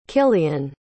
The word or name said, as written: cillian